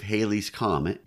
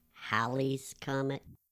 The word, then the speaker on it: Halley's Comet: if Halley's Comet was the Christmas star. – Actually, that should have been pronounced Halley's Comet.